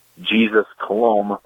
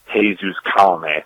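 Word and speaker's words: Colomé: I read it as Jesus Colom, but it actually turned out to be pronounced Jesus Colome.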